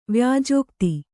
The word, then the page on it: ವ್ಯಾಜೋಕ್ತಿ: vyājōkti